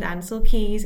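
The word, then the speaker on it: Ancel Keys: the whole situation with Ansel Keys. Is that how you say his name? – Yeah, that's right.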